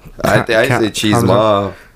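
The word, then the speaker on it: Chimaev: Wait, how do you say his name again? – Cheesemov? – Yeah. – I say Cheesemov.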